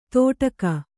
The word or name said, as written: ತೋಟಕ